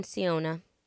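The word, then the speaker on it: Sionna: Shannon Siona. – Thank you. S-H-A-N-N-O-N-S-I-O-N-N-A.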